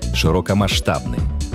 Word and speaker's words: широкомасштабный: Одно из них – широкомасштабный. Обратим внимание на ударение только на предпоследний слог. Никак иначе.